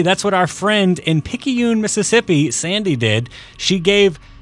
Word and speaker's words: Picayune: Picayune. – Picayune.